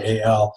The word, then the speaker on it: Eyal: There's this this guy named Nir Eyal or Eyal. I'm not sure if I pronounce his name right. It's Eyal.